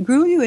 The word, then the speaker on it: Grullo: And of course, the double L is is like a Y. It's Spanish. – Grullo. It's yeah. a Spanish mm-hmm. term. And uh, the Grullo horse is very unique,